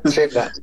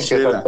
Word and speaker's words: Xela: Xela, se me va un poquito la pronunciación. – Chela, es en Xela.